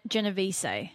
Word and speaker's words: Genovese: I hope I'm pronouncing that correctly. It's Italian. Genovese.